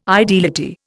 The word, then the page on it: ideality: Indian